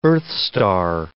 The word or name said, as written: earthstar